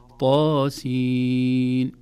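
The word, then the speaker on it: طس: إذن نقول طاء وليس الطاء، هي حرف طاء وضاء، لكن عند نطقها في أوائل السور تحذف الهمزة يصبح حرف ثنائي مد فقط، طا ويا حا را هكذا نقول طاسين.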